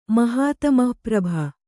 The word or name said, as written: ಮಹಾತಮಃಪ್ರಭ